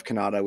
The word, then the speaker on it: Cannata: Um, and I'm on uh, Twitter at Jeff Cannata, which is spelled with two N's and one T.